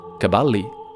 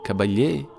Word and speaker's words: Caballé: Peter guarda il libretto di scena, si chiama Montserrat Caballé, ma com'è che si pronuncia? Caballi? Caballé? Caballé.